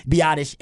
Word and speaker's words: Biadasz: Because, everybody knows at this point, Biadasz. And it ain't spelled like that.